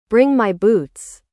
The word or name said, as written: bring my boots